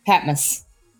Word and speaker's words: Patmos: Patmos. Is that how you say that? This is a library in Michigan, so I might have to ask about pronunciations. Yeah, Patmos.